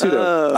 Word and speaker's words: pseudo: No, the P is silent. It's pseudo.